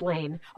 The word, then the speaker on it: Lahn: Is that how it's, well, it looks like Lahn. It's pronounced Lane. – Okay. – It's L-A-H-N, but okay. It's pronounced like Lane.